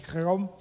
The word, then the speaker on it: Chełm: It's spelled C-H-E-L-M, like we would say Chełm, but it's actually Chełm.